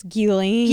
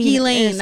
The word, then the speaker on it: Ghislaine: It's Ghislaine. – Ghislaine, that's what it was.